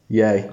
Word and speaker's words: Ye: Yay.